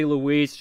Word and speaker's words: Luis: how to say Louise, just think Selma and Louise, but how... The movie Selma and Louise is spelled, that's not how you spell it, just L-U-I-S.